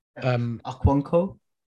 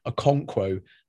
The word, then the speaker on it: Okonkwo: And then Arteta and Edu need to assess whether someone like Carl Hayne or Arthur Okonwo. – Okonkwo? – Yeah, sorry, that was a terrible pronunciation, wasn't it? Okonkwo, I should say, are going to step up, or whether they can find a sort of number three.